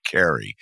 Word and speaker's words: Kari: it's her name is spelled as though it were Kari, but she pronounces it Carrie, and she's no relation whatsoever to the lead character in Stephen King's classic novel, which is just celebrating its 50th anniversary.